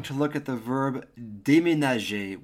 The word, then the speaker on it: déménager: Pronunciation, déménager.